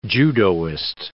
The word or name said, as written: judoist